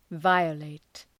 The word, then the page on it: violate: ‘vaıə,leıt